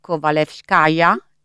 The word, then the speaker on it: Kovalevskaya: Allora, lei ha scritto diversi graphic novel, tra cui Marie Curie, edito da Becco Giallo nel 2017, Sofia, non so se sono in grado di pronunciarla, Kovalevskaya, giusto? – Sì, sì. Ecco. Sì, sì, giusto.